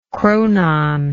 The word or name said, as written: chronon